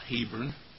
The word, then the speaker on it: Hebron: And I've been in cities like Hebron, which we generally pronounce Hebron, and Bethlehem and the divided city of Jerusalem.